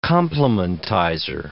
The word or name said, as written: complementizer